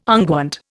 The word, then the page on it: unguent: Indian